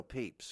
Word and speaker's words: Pepys: his name is spelled P-E-P-Y-S. And you go, well, that's the surname Pepys. And I go, I know, and it used to be that. And he got teased terribly. Samuel Pepys, like that. Samuel Pepys, then he poo-poos. And he's like, I couldn't take it anymore, so I'm Pepys. And I go, that's cool. I suggested it, actually. I go, I know a good pronunciation. Like people. We say that is short for people.